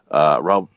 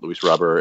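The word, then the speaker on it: Robert: And then they got Robber, I guess is how he pronounces it, Luis Robber.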